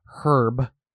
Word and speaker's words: herb: Like it clearly is H-E-R-B, but they're like, the H is silent.